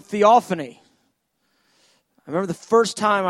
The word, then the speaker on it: theophany: It's just a theophany, four-syllable word. Sounds like, it's a four-syllable word.